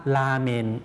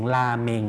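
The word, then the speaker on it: รา เม น (ラーメン): ราเมนครับราเมนครับคนญี่ปุ่นคนไทยเราออกเสียงราเมง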